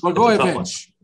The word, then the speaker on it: Blagojevich: In 2013, he began partnering with Alex Blag- Blagojevich. Did I say it right? Blagojevich, close. Blagojevich,